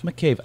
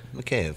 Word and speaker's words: McCabe: I've mispronounced your last name for as long as I've known you. – That's all right. McCabe. It sounds more Irish than Russian.